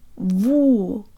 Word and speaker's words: сегодня: Сегодня.